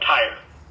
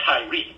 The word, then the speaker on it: Tyre: It's pronounced Tyre, but it's Tyre. It's T-Y-R-E.